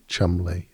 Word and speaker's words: Cholmondeley: or Cholmondeley is pronounced Chumley, or Magdalene is pronounced Maudlin, and Worcester is pronounced Worcester.